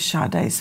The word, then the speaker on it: Sade: Go to Justice for Sade, and that is spelled S-A-D-E, like you would say Sade, like sage. It's pronounced Sade, Justice for Sade, our fallen angel.